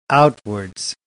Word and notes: outwards: American English

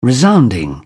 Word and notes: resounding: British English

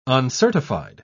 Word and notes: uncertified: British English

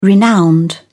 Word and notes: renowned: British English

